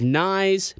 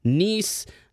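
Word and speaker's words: Knies: It is Nyes, not Nice.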